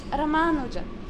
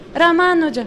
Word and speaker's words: Ramanuja: So we have Ramanuja. It's not Ramanuja. If you say Ramanuja, it means you make the second syllable short. So it's Ramanuja.